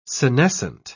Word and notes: senescent: British English

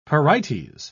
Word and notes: pyrites: British English

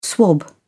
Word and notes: swab: British English

